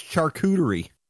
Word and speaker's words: charcuterie: Can you pronounce charcuterie for me, please? – Charcuterie? – Oh, he did it this time. – That's right. – Yeah, and also correct.